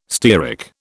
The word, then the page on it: stearic: British English